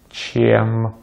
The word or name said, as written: чем